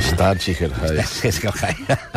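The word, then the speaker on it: Staatssicherheit: Aquell organisme repulsiu que es deia el Ministerium für Stasicherheit. No ho sé pronunciar, perdó. – Stasicherheit.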